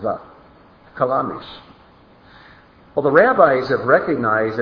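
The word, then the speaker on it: kalamish (חלמיש): Now, the Hebrew word for flinty rock is the word kalamish. And it's spelled like this. Ket lamed mem yad shin. Kalamish.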